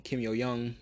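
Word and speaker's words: Kim Yo-jong: It looked like it was going to be Kim Yo-jung. Well, Kim Yo-young, if we're going to be specific with the pronunciations.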